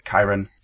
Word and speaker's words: Charon: So the largest moon is Charon, which is spelt C-H-A-R-O-N, and is pronounced Charon or Chiron,